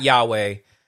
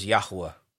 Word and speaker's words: Yahuwah: But now, after reading the Hallelujah Scriptures and more Hebraic scholars and teachers and stuff, I prefer to use the actual name of God, which is Yahuwah, or you know, it's not Yahweh. The Tetragrammaton is Yod-Heh-Vav-Heh, or, you know, Yahovah, but the actual pronunciation is Yahuwah.